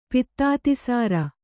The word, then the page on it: ಪಿತ್ತಾತಿಸಾರ: Kannada